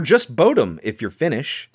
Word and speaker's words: Bodom: But consensus is that it's pronounced Bodum in Finnish. – Bodum.